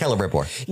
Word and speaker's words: Celebrimbor: Did I say that right? – I think so. I don't think anybody says that after like 1999, but in that time era, you would have said it properly. Yes. – Well, I mean, Celebrimbor, is it? – Oh yeah. Yeah. You crushed it.